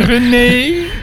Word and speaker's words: René: Ja, dan heb je net hoe je de klemtoon legt. Ja. René.